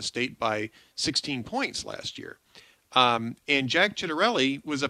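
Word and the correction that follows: Ciattarelli: a former, I think it's Chattarelli is how it's pronounced, actually.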